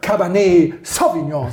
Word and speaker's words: Cabernet Sauvignon: Aber niemand sagt Cabernet Sauvignon, sondern alle sagen Cabernet Sauvignon. – Ja, genau. – Andere Betonung.